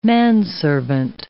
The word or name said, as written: manservant